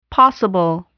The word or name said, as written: possible